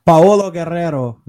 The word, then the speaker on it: Paolo Guerrero: Como é que fala Paulo Guerreiro em espanhol? – Paolo Guerrero, ele fala assim.